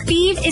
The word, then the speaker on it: Fives: Fieve is spelled F-I-V-E-S, like the number, but pronounced Fieve.